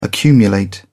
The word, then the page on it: accumulate: British English